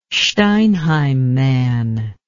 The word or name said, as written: Steinheim man